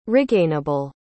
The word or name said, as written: regainable